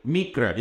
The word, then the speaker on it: miqra: The word convocation in the Hebrew is mikra, M-I-Q-R-A, mikra. It occurs 23 times in the Old Testament. This is from Vine's Expository Dictionary of Biblical Words. From Vine, it says the word mikra implies an official summons to worship, an official summons to worship, an appointment.